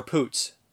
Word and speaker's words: Poots: I always say Imogen Potts' name wrong, but, or Poots.